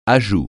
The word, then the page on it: ajout: francais